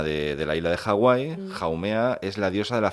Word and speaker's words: Haumea: Se pronuncia Haumea porque decimos los españoles que se pronuncia así, a saber cómo lo dice un americano. – Sí, bueno, decimos Hawái y se escribe igual, o sea, tiene que ser así.